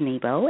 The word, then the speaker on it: Nebo: My name is spelled T-A-N-Y-A, and the last name, of course, is Nebo, N-E-B-O.